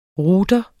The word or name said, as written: ROUTER